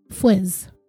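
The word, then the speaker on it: Fuisz: And his name is spelled F-U-I-S-Z. I looked it up and it looks like it's pronounced Fuiz.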